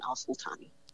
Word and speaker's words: Alsultany: I just joined a few weeks ago, but you can find me on Twitter too at Evelyn Alsultany. – Very good. Evelyn Alsultany. And I'll spell that. Of course, it's Evelyn, E-V-E-L-Y-N. And Alsultany is spelled A-L-S-U-L-T-A-N-Y. Correct? – Correct.